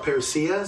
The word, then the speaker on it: parousia: Um, it's an English uh, translation of the Greek word parousia. Perhaps you've or, or, or parousia, some people pronounce it.